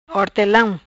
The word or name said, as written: Hortelam